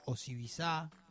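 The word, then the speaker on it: Osibisa: El nombre Osibiza, en realidad se pronuncia Osibiza, significa, según sus miembros, cruce de ritmos que estallan de alegría.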